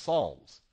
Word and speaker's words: Psalms: The one has a P first, and then an S, but it's pronounced Psalms.